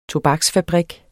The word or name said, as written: TOBAKSFABRIK